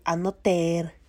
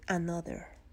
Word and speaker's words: another: No pronuncian así, mixes Se dice another, another. Así que, que suene la T, no suena tan como tacos. Suena como, casi como una T. Another, another. ¿Sí? ¿Sí entienden la diferencia? Another, sí, another, ya la vi.